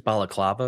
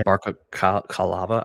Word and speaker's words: balaclava: Yeah, I mean, I just, it's a pun I can't, he's trying to, like, the joke is balaclava or whatever, where it says the full mask, but it's barca calava.